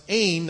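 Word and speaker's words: ain (ἦν): We say E-N, pronounced ain. That's the word was.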